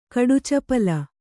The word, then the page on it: ಕಡುಚಪಲ: kaḍucapala